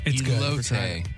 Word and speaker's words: elote: It's elote. Elote is what people are saying to pronounce it.